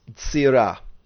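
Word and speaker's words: tzira (צרעה): Now the Hebrew word hornet is tzira, and that's spelled zari resh ein he.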